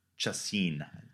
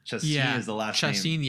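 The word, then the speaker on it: Chacín: and I think it's Chacin. – Chacin, yeah, you're saying that right.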